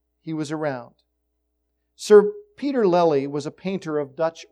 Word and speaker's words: Lely: Have you ever heard of a painter named Sir Peter Lely? I never had heard of him before, didn't know anything about him. Sir Lely, and I hope I'm saying it right, L-E-L-Y, Lely or Lely, Lely I'll call him, was born about 400 years ago.